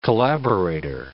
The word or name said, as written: collaborator